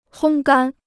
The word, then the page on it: 烘干: hōng gān